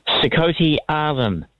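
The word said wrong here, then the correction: Sikhote-Alin: Sikote -Alin, and that's in a similar area.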